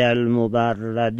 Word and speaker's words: المبرد: و من ها لام المستغاثه عند المبرد، عند المبرد هم به کسر را جایز خوانده بشه هم به فتح را. اونی که ما بیشتر تلفظ میکنیم عند المبرد